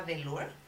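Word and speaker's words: The Lure: Delur, ¿cómo se escribe? T-H-E-L-U-R-E, e, de Lure. Ok.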